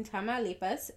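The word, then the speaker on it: Tamaulipas: Tamalipas. Okay. You're putting an extra T in there.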